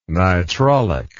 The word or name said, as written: nitrolic